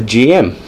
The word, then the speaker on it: GM: Like the GM. And also, which is actually normally pronounced Jim, which is super weird.